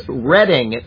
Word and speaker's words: Reading: And Reading. I said Reading. I know that it's Reading. And I convinced myself that I was going to say it correctly, and then I said the opposite. It is Reading, Pennsylvania, like the Reading Railroad on the Monopoly set.